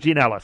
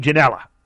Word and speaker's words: Ginella: Now, I believe the Spanish announcers that I've watched pronounce his name Ginella. Ginella. So Francisco Ginella. So I hope I'm getting that right, the last name,